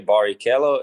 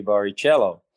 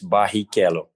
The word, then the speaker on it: Barrichello: It depends where you are in the world, because in England, if you want to say Barrichello, it's tough, so they say Barrichello. And in Portuguese, Brazilian Portuguese, it's Barrichello with a two R's pronounced a he. But then, you know, I my name is different everywhere. But the most common is Barrichello.